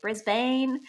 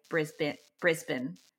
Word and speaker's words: Brisbane: So I understand why whenever I'm speaking to people in the US in particular, they always say Brisbane. And I'm like, that is actually how it's spelled. It probably should be said like that, but we just say Brisbane, almost like a bin.